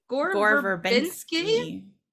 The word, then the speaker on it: Gore Verbinski: When casting the role for Will Turner, the role of Will Turner, it was down to Orlando Bloom, Heath Ledger, Gore, is that how you say his name? – Gore Verbinski.